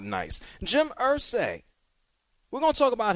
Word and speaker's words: Irsay: Shep, how do you say Irsay, Irsay? Which one is it? – You know what it is. Irsay.